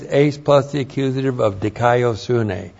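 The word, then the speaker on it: Dikaiosune (δικαιοσύνη): resulting in dikaiosune. That's the right path. D-I-K-A-I-O-S-U-N, long E. Dikaiosune, that's the Greek word righteousness.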